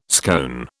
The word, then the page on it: scone: British English